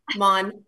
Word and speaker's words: Maughan: It's Maughan, really close.